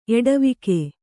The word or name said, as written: ಎಡೆವುಗು